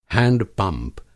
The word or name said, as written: हैंडपंप